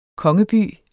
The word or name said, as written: kongeby